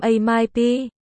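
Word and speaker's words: ma mai pi (妈卖批): Trong đầu toàn là MMP, chấm chấm, mụ bán phê, ma mai pi, phát âm là ma mai pi.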